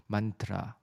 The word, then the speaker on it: mantra: 好，慢其实是心智，那 tra 它是一个震动，它念起来呢，因为呃我们一般 mantra 的这个发音，它比较。呃，比较像是这个呃西方在读音的音节，那其实它原本的发音应该是 mantra，mantra，它的后面是一个呃震动音 mantra，好 t r a，那这个这个后面这个是一个震动跟一个方向，就是 t r a 的意思，嗯，所以这个 mantra mantra 的科学基础呢，是建立在声音是一种能量形式的认知。